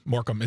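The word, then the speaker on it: Morecambe: Morcombe.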